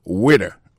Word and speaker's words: waiter: Alors moi, je prononce le waiter. Waiter, ça veut dire accent tombé sous le premier syllabe là. Waiter. W-A-I-T-E-R.